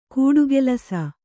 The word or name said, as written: ಗಟ್ಟಿಕೆಲಸ